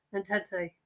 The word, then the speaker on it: Entente: Well, I may be saying that wrong. Entente? – Okay. Well, it's not going to be England and Germany because they didn't like each other. – There's the spelling, look. – Entente. – Yeah.